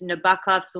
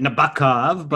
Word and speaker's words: Nabokov: which of course is Nabokov's Lolita. – I don't know if you pronounce it Nabokov, but Nabokov, right? – I can promise you. – Rhyme with fuck off.